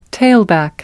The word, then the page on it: tailback: American English